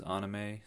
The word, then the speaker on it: anime: anime is usually, I think, how I refer to it, but I know the right way to pronounce it is anime, so I try to say it that way, but I think I'm only because I'm being recorded.